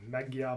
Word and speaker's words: Magyar: Magyar Bulls? – Yeah, it's pronounced Magyar. – Magyar. – It does look like Magyar.